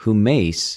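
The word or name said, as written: ὑμεις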